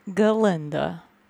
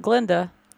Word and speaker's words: Galinda: And I guess what we'll call chapter one, Galinda. Not Glinda. – Galinda. – Galinda. – Three syllables.